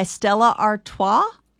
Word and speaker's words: Stella Artois: Bud Light is second, followed by Stella Artois.